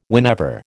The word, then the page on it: whenever: American English